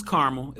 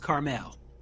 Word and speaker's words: Carmel: So East Coast is Carmel. If we're in the West Coast, it's Carmel.